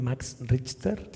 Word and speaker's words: Max Richter: ¿Max Richter? – Sí, sí, sí.